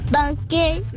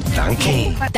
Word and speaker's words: Danke: Mis tres palabras son hello, banque, don arigato. – Danke.